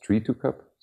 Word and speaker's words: 3-2-Cup: is actually pronounced tree to cup. So it means that we're actually there to help the supply chain between the tree and the to the cup.